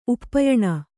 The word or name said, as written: ಉಪಾಯನ